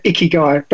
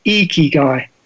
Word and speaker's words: ikigai: I say ikigai, but I think it should be ikigai. So, it's a kind of eek guy.